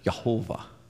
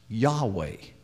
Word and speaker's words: Yahweh: and came up with Jehovah. But it's actually probably pronounced Yahweh. We would guess at that based upon the term Hallelujah, which means praise to Yah. So we would understand this four-letter term to be Yahweh.